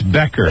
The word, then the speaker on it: Becker: I love Pecker. – It's Becker. Well, you know, I know you're a little baby.